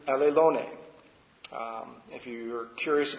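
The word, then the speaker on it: allelone (ἀλλήλων): And again, it's pronounced allelone.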